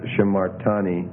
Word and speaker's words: shimartani: And then what's happening here is you can see you're getting a reduction again in the first syllable because of the accent shift. The accent's going down one syllable, so it's shimartani.